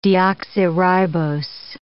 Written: deoxyribose

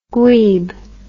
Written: gweeb

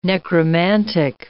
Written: necromantic

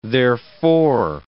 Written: therefor

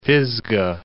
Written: Pisgah, Mount

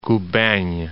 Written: Kuban'

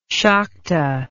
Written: sakta

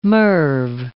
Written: MIRV

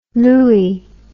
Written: looey

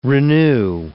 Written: renew

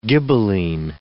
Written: Ghibelline

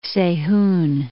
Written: Seihun

